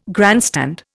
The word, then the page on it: grandstand: Indian